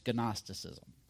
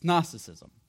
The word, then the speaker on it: Gnosticism: Silent G, unless you don't want it to be, and then it's Gnosticism. But nobody says that. It's Gnosticism.